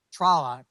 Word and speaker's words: Tralla: And so it was really an awkward read to keep every time you're reading a line and you see Tralla, parentheses, rhymes with Paula, blah, blah, blah, blah, blah, blah.